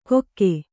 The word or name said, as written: ಕೊಕ್ಕೆ